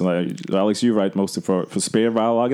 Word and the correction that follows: Spielverlagerung: that is that's almost um, for Spielverlagerung.